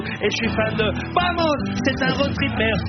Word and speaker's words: Vamos: Oui, ça s'écrit Vamos, mais ça se dit Vamos. En Espagne le V se dit B, mais ça c'est limite.